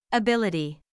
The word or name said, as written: ability